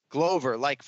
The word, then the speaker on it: Glover: Glover, like four-leaf clover.